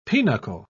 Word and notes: pinochle: British English

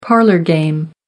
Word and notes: parlour game: American English

